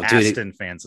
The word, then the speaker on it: Aston: Probably because you just interact with so many Austin fans. I'm sorry. Aston fans. That's my bad. Aston. I'm sorry. I mispronounced it.